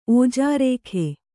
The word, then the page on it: ಓಜರೇಖೆ: kannada